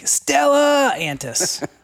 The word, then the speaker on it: Stellantis: We have to say it like Stellantis. Stellantis.